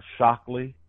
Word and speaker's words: Shockley: My name is spelled with no vowels, just K as in kite, M as in Mary, T as in Tom, .Shockley, S-H-O-C-K-L-E-Y, at howard.edu.